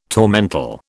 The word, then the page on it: tormentil: British English